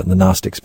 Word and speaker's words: Gnostic: that's some of what the Gnostics believe. – Yeah, no, that's right. These Gnostics, it's spelled with a G, G-N-O-S-T-I-C, Gnostic.